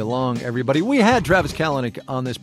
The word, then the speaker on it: Kalanick: Last name's pronounced Kalanick.